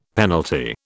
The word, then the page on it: penalty: British English